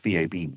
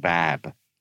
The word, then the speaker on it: VAB: I used to pronounce VAB VAB. – I was going to say, does one pronounce it the VAB? The VAB. No, that we don't say like that. – Yeah, we spell that one out.